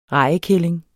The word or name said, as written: rejekælling